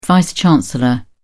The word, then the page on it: vice chancellor: British English